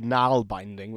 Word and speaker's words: naalbinding: The earliest known surviving pair of socks was created by a process called knal binding,